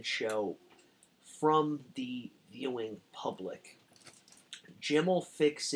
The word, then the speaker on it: Jim'll: Savile is credited with giving the name to this show, stating in numerous interviews that when it was presented to him, he pronounced it Jim'll Fix It. Producers initially thought that he meant the name of the show will be Jim Will Fix It. According to Savile, however, it was Jim'll, J-I-M-apostrophe-L-L.